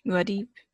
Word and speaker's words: Muad'Dib: Muad'Dib. – Yeah, Muad'Dib.